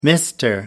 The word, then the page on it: mr. moto: American English